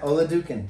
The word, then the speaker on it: Oladokun: So I'm not sure how to pronounce it, but it looks like it's Joy Oladokun. – Yeah, Oladokun.